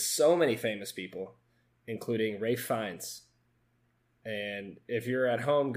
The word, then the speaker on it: Ralph Fiennes: And his name is Ralph Fiennes. And I spent a lot of time looking up how to say his name. So let my homework, you know, feed you. It's called Ralph Fiennes.